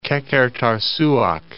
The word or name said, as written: Qeqertarsuaq